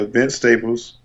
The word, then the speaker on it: Vince Staples: And I think it was it Vince Staples? Vic Staples? I can't never say his name right. – Yeah, Vince Staples.